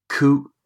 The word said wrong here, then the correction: co-option: How do you pronounce that? – Coup d'état. – Co-option. – Co-option. – Sorry, co-option. Elections only occur if following the advertisement of the vacancy for 14 days, 10 electors send a written request to the returning officer.